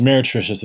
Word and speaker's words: meretricious: Our first word is meretricious. It's spelled M-E-R-E-T-R-I-C-I-O-U-S. That's M-E-R-E-T-R-I-C-I-O-U-S. It's phonetic, meretricious, meretricious, meretricious.